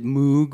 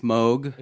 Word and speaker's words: Moog: Some of you might pronounce it Moog, but yeah, it's Moog. – It's M-O-O-G.